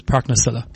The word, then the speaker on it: Parknassilla: So the correct pronunciation in English is Parknassilla.